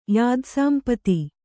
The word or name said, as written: ಯಾದಸಾಂಪತಿ